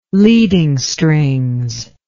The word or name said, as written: leading strings